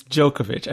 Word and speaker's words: Djokovic: And really, it's Djokovic. I keep saying it wrong.